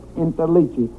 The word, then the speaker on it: Interliggi: My name is Joseph Interligi. I-N-T-E-R-L-I-G-G-I.